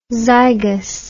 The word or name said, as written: zygous